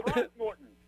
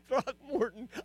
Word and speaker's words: Throckmorton: Throckmorton. – Yeah, Throckmorton.